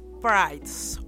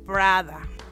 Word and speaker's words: Prydz: Él es Erik Prydz o Erik Prada. Según en la región es como lo pronuncian.